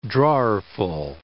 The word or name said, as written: drawerful